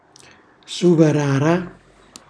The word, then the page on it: suvarara: Calabrese